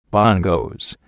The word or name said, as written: bongos